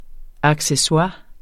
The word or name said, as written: ACCESSOIRES